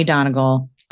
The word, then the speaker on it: Donegal: Glenties, County Donegal, Ireland, 2012. Is that right? – Yes. – Glenties. Okay. – I think. I know it's County Donegal. You pronounced that correctly, but I don't know about the other one.